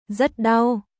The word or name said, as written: Rất đau